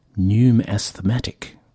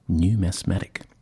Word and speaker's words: Pneum Asthmatic: Pneum Asthmatic. So it's asthmatic with P-N-E-U-M in front of it, Pneum Asthmatic, which I really enjoyed, actually.